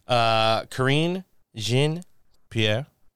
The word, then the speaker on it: Karine Jean-Pierre: Karine Jean-Pierre.